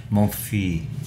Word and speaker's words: Monfils: How do you pronounce it? – Are you okay? Sorry, I was just clearing my throat. Monfils.